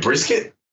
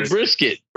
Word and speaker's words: Brissett: And they were like, Brisket? – Yeah. – So I was like, yeah, Mama's. – Hey, Jacoby Brissett.